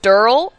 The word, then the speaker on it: Daryl: His name is Daryl, but he pronounces it Durl, and he will correct you every time.